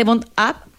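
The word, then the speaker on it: BondUp: En general, o sea, si tú te metes a la página de Bond App, Bond tiene, se escribe B larga O-N-D-U-P, uh-huh.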